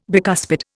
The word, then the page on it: bicuspid: Indian